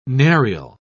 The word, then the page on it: narial: British English